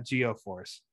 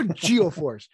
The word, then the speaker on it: Geo-Force: It was G-Force, not Geo-Force. All right, Geo, that's how it's pronounced. It's Geo, G-E-O-Force.